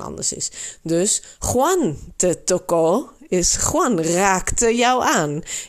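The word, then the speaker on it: tocó: Juan te toco. Dan komt die klemtoon dus op ko.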